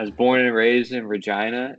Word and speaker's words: Regina: Did you know there's a town in Canada called Regina? – I did not know that. – It's pronounced Regina, but so when I lived there for a little while, worked there.